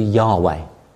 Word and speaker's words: Yahweh: It's thought probably to be Yahweh is the likely pronunciation of these four letters, the way they would vocalize them.